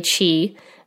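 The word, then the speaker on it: qi: I, think I'm pronouncing it correctly, Chi, um, even though it's spelled Q I.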